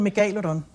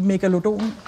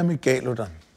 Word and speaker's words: megalodon: Jeg plejer at bruge de latinske udtaleregler. Mm-hmm. Så hedder den megalodon. Men af en eller anden grund, så har vi danskere bare lyst til at sige megalodon, eller megalodon.